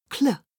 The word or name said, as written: cl